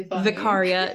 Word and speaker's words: Vicaria: That's and it's really Vicaria, not Vicaria. – Yeah, not Vicaria, like live vicariously. It's I was pronouncing it Vicaria because I always do a long A, like all.